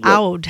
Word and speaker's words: oud: Oud.